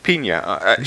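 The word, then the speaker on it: Peña: Elizabeth Pina, or Pina, I don't know how you pronounce her name. – Pina.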